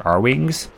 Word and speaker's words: R-Wings: I always said air wings as a kid, so it's always hard for me to remember that's actually pronounced R-Wings.